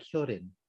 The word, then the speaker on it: Hyolyn: I know it's really tough because it's spelled with an L, but the L sound in Korean, there is no really L sound. It's more of an R-ish kind of sound, so it's more like Hyolyn.